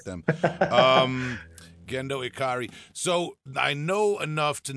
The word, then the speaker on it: Gendo Ikari: I'm gonna try Gendo Ikari. Did I say it right? – Ikari. – Gendo Ikari. All right, I'm trying. – Yeah, that's it. – Gendo Ikari.